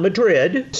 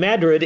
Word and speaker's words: Madrid: Madrid is a really cool little town, not Madrid, spelled the same as Madrid, but Madrid. It's right along the highway there.